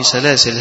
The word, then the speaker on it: سلاسل: فهتلاقي في المصاحف اللي في أيدينا سلاسل لام ألف، والألف عليها سكون، لدائرة مقفولة معناها إنها لا تنطق،